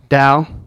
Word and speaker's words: Tao: Tao to Matt. – Dow. – God damn it. – Dow to Matt.